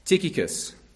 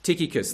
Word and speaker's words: Tychicus: And so firstly, our first man, Tychicus. Tychicus, the CH is pronounced with a K in the Greek. Tychicus is the other pronunciation.